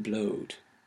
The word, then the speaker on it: blood: So if we go back to our example, good and blood rhyme because both were pronounced goad and blowed.